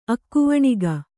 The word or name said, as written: ಅಕ್ಕವಣಿಗೆ